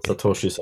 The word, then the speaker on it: satoshi: Satoshis är också populärt. – Satoshi, kanske. – Mm. Just det. Just det. Om pluralformen skulle följa de japanska grammatiska reglerna så ska det uttalas satoshi. Eller satoshisa.